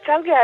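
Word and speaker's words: Čalgija: E poi scusami ancora per la pronuncia, c'è la Kalgija? Ok, perfetto. Che è un altro genere molto particolare. – Sì, ecco, la Chalgia è esattamente un genere che proviene direttamente dalle città turche, quindi dai ritmi di Istanbul.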